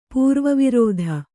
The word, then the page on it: ಪೂರ್ವವೃತ್ತಿ: kannada